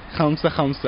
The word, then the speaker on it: khamsa khamsa: How do you say that? – Khamsa khamsa.